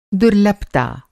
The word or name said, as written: दुर्लभता